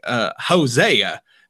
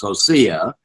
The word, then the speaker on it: Hosea: And there in Texas, we call this book Hosea. Now, you have a slightly different pronunciation. – Well, yes. It depends on where you were born in relationship to the Mason-Dixon line. If you came from north of the Mason-Dixon line, it's Hosea.